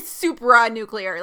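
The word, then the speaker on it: supranuclear: It's because, yeah, when I heard that, I was like, super nuclear? Like, that sounds kind of like superhero-y. – Yes. – It's actually supra nuclear, like S-U-P-R-A.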